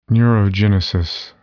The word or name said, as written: neurogenesis